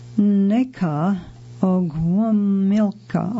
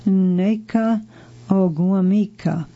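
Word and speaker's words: Nneka Ogwumike: NECA Ogwumilka, I'm going to spell that for you, N-N-E-K-A, first name, O-G-W-U-M-I-K-E, last name. I'm going to try it again, Neka Ogwumika.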